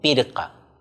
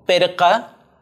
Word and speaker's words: pirca: Por ejemplo, la palabra pared en quechua se debe escribir con i, algo así como pirca, pero la forma pronunciada varía, como perca o como perca.